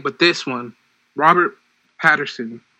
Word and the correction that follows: Pattinson: How do you say his last name? – Pattinson. – Pattinson? – Yes.